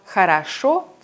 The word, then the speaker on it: хорошо: Im Wort хорошо zum Beispiel liegt die Betonung auf der letzten Silbe. Deswegen wird das letzte O wie O ausgesprochen. Das mittlere O wird zu einem reduzierten A und das erste O zu einem AI-Laut. Хорошо.